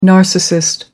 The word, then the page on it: narcissist: American English